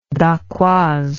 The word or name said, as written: dacquoise